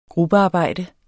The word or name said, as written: gruppearbejde